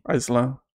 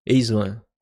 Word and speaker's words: Aislan: Vou perguntar de novo, eu falei certo o nome? – Aislan. Aislan. Aislan.